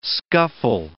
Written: scuffle

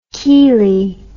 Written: keelie

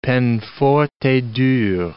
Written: peine forte et dure

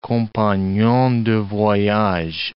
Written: compagnon de voyage

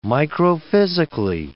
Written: microphysically

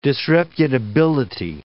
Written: disreputability

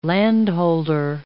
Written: landholder

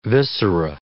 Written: viscera

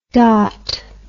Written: dawt

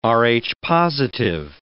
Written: Rh-positive

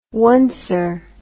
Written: oncer

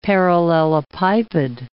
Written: parallelepiped

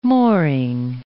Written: mooring

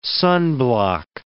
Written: sunblock

